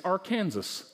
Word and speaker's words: Arkansas: They don't even know how to say their name. It's Arkansas.